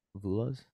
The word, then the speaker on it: Vula's: So, I will say Vula's. – How do you say that? – Greek. – Vulas? – Vula's. Yep.